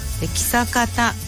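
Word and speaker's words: 象潟: 今回は道の駅木坂田眠の丘を紹介します。木坂田ってね、象にあと新潟のガタって書いて木坂田って読むんですよね。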